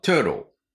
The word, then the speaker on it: turtle: Turtle. Turtle. これ亀ですね。日本語だとタートルネック、タートルって言いますけど、実際の発音はトゥロー、トゥローです。